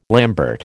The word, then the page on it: lambert: American English